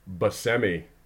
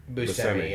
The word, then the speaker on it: Buscemi: You mispronounced his last name. It's Buscemi. It's Buscemi. Yeah, I would say Buscemi.